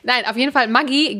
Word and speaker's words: Maggi: Und der Vater von diesem Julius Maggi, der war Italiener, was also eigentlich heißt, weil es gibt ja auch die italienische Schweiz, das müsste eigentlich Maggi ausgesprochen werden. Maggi.